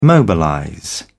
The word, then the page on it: mobilize: British English